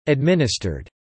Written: administered

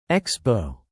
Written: Expo

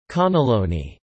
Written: caneloni

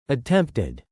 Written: attempted

